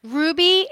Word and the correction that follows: Roby: Did I say it right, Desiree? – Almost. Desiree Roby. – Roby. Roby, yes. Okay.